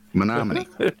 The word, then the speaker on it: Menominee: He said, look at it. It says, me no money. And I've never forgot that. Menominee.